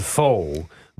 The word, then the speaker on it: pho: It's it's spelled pho but I learned it's actually pronounced pho, and uh, that's delicious.